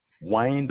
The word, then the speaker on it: Wayne: Yes, my name is Wayne Bland. – You say your name is what, sir? – Wayne, W-A-Y-N-E, Bland, B-L-A-N-D.